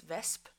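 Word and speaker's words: wesp: In Dutch, it's vesp. Yeah, vesp. And in Norwegian, it's veps. So you turn around two letters, and I didn't notice because that's just how I always pronounced it.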